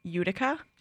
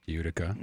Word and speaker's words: Utica: they lost in an overtime shootout against, I said this wrong last time, Utica. – Utica.